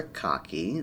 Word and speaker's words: Kake: We can talk a lot about the titular cocky, that's spelled K-A-K-E, but suffice it to say that he was Tom's ideal man and pretty transparently an idealized self-insert.